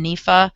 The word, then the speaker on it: NEFA: I call them NEFA. That's how I pronounce that acronym.